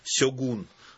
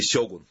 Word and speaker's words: Сёгун: Конечно, большинство из тех, кто про него хоть что-то знал, знакомы с романом Джеймса Клавелла «Сёгун». Или «Сёгун». – Нет у них прямого ударения. И то, и то правильно.